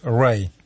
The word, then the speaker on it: rye: В англосаксонских языках это «рай», пишется «R-Y-E», если по-английски сейчас даю азбуку, алфавит.